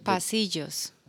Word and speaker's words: pasillos: The pasillos. – The pasillos? How do you spell that? – P-A-S-I-L-L-O-S.